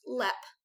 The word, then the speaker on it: Leap: And so it is spelled Leap Castle, but it is pronounced Lep Castle.